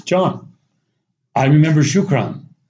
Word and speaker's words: shukran: And I said, well, here in Egypt, it's very simple. They just say shukran, just 2 syllables, shukran, shukran.